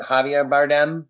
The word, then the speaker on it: Javier Bardem: But like, a great example would be in No Country for Old Men when, I don't know how to say his name, Javier Bardem, right?